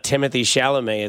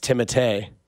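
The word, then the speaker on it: Timothée Chalamet: It's also not Timothy Chalamet. It's Timotei.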